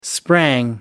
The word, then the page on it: sprang: American English